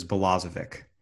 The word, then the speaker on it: Balazovic: By the way, got the pronunciation. It is Balazovic. Thank you very much. MLB official pronunciation guide.